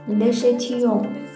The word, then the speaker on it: Les Chétillons: 今日教大家雪地龙单一园的法语发音：Le Chatillon。 Le Chatillon，Le Chatillon，你学会了吗？